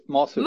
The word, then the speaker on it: Mazur: Ulf Mas Masur.